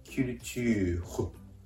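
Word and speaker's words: culture: キュルチューホ、キュルチューホと発音するところがポイントです。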